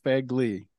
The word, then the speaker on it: Fegley: Okay, Fegley.